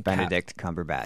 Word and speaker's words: Cumberbatch: Benedict Cumberbatch.